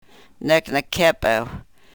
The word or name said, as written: nek ne-kepew